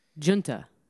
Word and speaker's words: Junta: Hard J, soft U. Junta. Yeah, so that is how you say it.